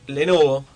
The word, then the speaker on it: Lenovo: Bueno, era Alexander Raber, gerente comercial de Lenovo, ¿se dice así? – Sí, de Lenovo Argentina.